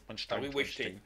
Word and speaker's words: Weinstein: bien sûr, il y a le le déjà condamné Weinstein, comment on prononce Harvey Weinstein, pardon, Weinstein,